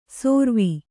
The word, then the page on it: ಸೋರ್ವಿ: sōrvi